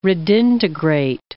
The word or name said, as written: redintegrate